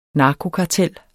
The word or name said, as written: narkokartel